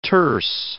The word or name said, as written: terse